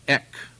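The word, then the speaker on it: ek (ἐκ): Now, notice the little word for of in the Greek. It's pronounced ek.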